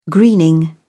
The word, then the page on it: greening: British English